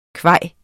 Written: kvaj

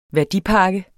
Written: værdipakke

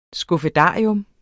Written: skuffedarium

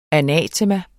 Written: anatema

